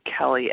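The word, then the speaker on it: Kelley: My name is Kelly S., spelled with an E-Y, and I'm recovered compulsive overeater in Oklahoma and bulimic.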